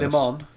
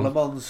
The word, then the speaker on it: Le Mans: Le Mans. – Well, go on, you say it first. – Le Mans. – That's not how you said it originally, but yes, right. – Le Mans